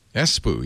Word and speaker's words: Espoo: Espoo, yeah.